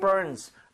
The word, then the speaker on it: burn: 이렇게 뭔가 통증 얘기를 할 때 특히나 뭔가 쓰리고 따가운 거 얘기를 할 때 it burns 라고 얘기를 많이 쓰게 됩니다. – 네. – 얘도 발음할 때 R 살아있죠. – 예. – 그래서 아까 warn처럼 혀를 당기고 은 하고 아하. – finish 하셔야 돼요.